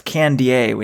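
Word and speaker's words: Kandie: And I was like, Kibiwot Candy, which is how we pronounce his name. Wrong on the Malcolm Gladwell podcast as well. It's Candier, we now know, but I didn't even know the correct name at the time.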